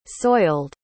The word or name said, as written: soiled